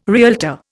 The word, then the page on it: realtor: Indian